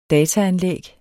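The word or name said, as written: dataanlæg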